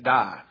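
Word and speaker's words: die (δεῖ): It's a word spelled D-E-I, pronounced die.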